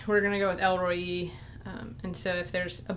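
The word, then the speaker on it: El Roi: So Elroyi, is that right? She's our pronunciation genius. – Don't go that far. I pronounce it Elroyi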